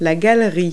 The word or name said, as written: roof rack